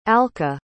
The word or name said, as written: alka